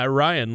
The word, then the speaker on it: Orion: Yeah, Orion, like the star cluster.